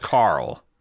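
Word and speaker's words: Qarl: I think it's just pronounced Carl. – Carl, okay. – Yeah, like the Walking Dead, Carl.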